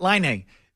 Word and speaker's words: Laine: Laine.